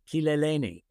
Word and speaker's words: Kileleni: And it's Tupande Kileleni. And the good thing about Swahili, which is Kileleni, is pronounced just like Spanish. So if you can speak any Spanish, you can say the words.